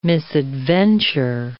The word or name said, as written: misadventure